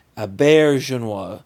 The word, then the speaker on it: Auberjonois: Would that be René Auberjonois? – Yes. I looked it up. René Aubert, it's the accent on the bear. Look at the phonetic. Auberjonois.